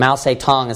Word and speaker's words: Mao Zedong: And his name is Mao Zedong. Mao Zedong, you can pronounce it. Sometimes it's pronounced in English. But Mao Zedong is how it's pronounced in Chinese, in China.